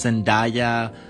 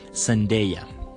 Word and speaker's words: Zendaya: Por ejemplo, Hugh Jackman, Zendaya, por cierto, esa es la correcta pronunciación. No sé si ya lo sabían, pero no es Zendaya, como si fuera papaya. No, no, no, nada de eso. Es Zendaya.